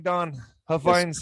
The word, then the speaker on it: Huffines: I am here with former state senator Don Huffines. Did I say that right, Don Huffines? – Yes, got it.